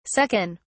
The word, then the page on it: suck in: British English